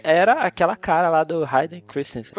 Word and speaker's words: Hayden Christensen: E a última identidade Jedi que as pessoas lembram é com, quando ele era o, o Hayden Christensen, Christensen, né? Como é que fala? – Sim, assim? o Hayden Christensen.